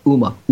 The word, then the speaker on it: Huma: And get this, George and Paul, they also took Huma Abedin's, or I guess you say it, Uma, you don't pronounce the H.